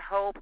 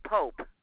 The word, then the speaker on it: Pope: My last name is pronounced P as in Paul, O, P as in Paul, E. Not Hope, it's Pope. – Like Pope, P-O-P-E.